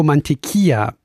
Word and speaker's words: Mantequilla: Rio Mantequilla, nicht Tequila. Das L spricht man natürlich nicht mit.